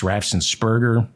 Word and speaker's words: Raffensperger: The infamous January 2nd, 2021 call to Georgia's Secretary of State Brad Raffensperger, I mispronounce his name. I think it's Raffensperger.